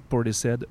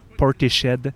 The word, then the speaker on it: Portishead: Portishead va le prononcer Portishead et ça me rend violent.